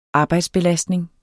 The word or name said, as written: arbejdsbelastning